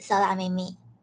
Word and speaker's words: 空耳: 嗯，哦，刚刚一之米说的空耳也是一个日文的词，空耳的日文发音是色拉咪咪，写成汉字就是空耳，天空的空，耳朵的耳，原本的意思是幻听。